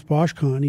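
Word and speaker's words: Boshkung: So the reason I know how to pronounce Boschcon, even though it's spelled Boschkung, is because our good friend of the show, Eric Duhacek, now works for The Athletic, based in Calgary.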